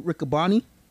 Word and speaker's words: Riccaboni: Ian Riccaboni.